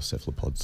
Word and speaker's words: cephalopods: On global proliferation of cephalopods, or cephalopods, some people might pronounce it.